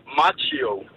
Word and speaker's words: Macchio: Så ham der, Ralph Karate Kid, hvordan udtaler man navnet? – Macho. – Så det er macho, ligesom at være macho? – Ja, og, macho.